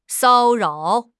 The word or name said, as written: 骚扰